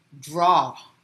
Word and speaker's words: draw: And as I told you, we spell draw as D-R-A-W. But the way we pronounce it may not be the same as how we spell the word. So as you can see, the pronunciation is not the same. It's actually a D-R-O, right? So draw aw. It's a short O, draw, right?